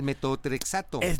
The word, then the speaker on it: metotrexato: Ah, el metotrexato. – Mira, le estaba dando vueltas porque no sabía cómo pronunciarlo, pero muchas gracias.